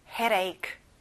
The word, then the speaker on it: headache: 자, 가운데 D 발음은 잘안 나요. 부드럽게 내보세요. headache.